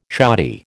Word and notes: shoddy: American English

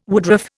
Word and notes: woodruff: Indian

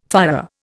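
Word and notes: führer: Indian

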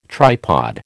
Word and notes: tripod: American English

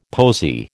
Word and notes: posy: American English

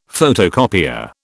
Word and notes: photocopier: British English

